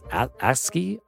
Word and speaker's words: ASCII: it's like um I don't know it's like so I'm highlighting the, the blank page and it's like like I guess ASCII am I even pronouncing that right? Yep, ASCII art.